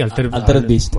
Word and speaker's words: Altered Beast: Altered Beast.